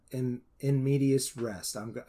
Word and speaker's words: in medias res: I don't want to give away too many details about it, but it is a story told with in medias res. I said it wrong. Sure I did. – No, I think that's right.